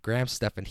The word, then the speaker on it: Graham Stephan: Yeah, Graham Stephan, G-R-A-H-A-M space S-T-E-P-H-A-N. Graham Stephan.